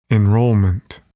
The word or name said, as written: enrollment